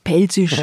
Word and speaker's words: Pfälzisch: Das P darf nie weglose. Das F konnte schon mal weglose, aber das P nie. – Auf Pfälzisch.